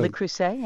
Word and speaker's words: Le Creuset: I got a Martha Stewart and I got a, is it Creuset? Is that how you say it? Or Creuset? – Le Creuset, yeah.